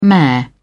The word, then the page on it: mare: British English